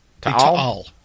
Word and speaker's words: Taal: Taal. – The Taal volcano has erupted.